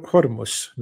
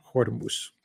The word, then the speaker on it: Hormuz: Iranian Kiddo says, in modern Farsi, we pronounce it Hormuz, not Hormuz.